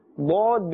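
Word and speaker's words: Dad (ض): Dad belongs to the category of huruf musta'aliyah, and therefore it must be pronounced with a full mouth.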